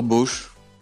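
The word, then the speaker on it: Bosz: Peter Bosch. Le O, puis ouais, le classique, hein, juste le. Vous voyez, on prononce pas le S par contre.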